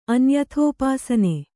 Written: ಅನ್ಯಥೋಪಾಸನೆ

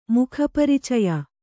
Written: ಮುಖಪರಿಚಯ

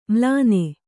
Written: ಮ್ಲಾನೆ

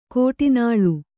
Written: ಘಟಾನಳ

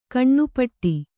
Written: ಘನಪಾಠ

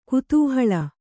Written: ಕುತೂಹಲಿ

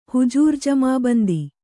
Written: ಹುಜೂರುಜಮಾಬಂದಿ

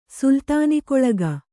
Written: ಸುಲ್ತಾನಿಕೊಳಗ

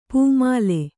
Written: ಪೂಮಾಲೆ

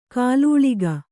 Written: ಕಾಲೂಳಿಗ

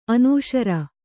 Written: ಅನುಸೌರ

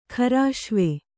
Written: ಖರಾಶ್ವೆ

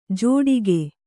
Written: ಜೋಡಿಕೆ

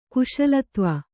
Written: ಕುಶಲತ್ವ

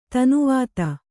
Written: ತನುವಾತ

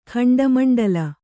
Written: ಖಂಡಮಂಡಲ

